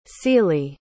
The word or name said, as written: celie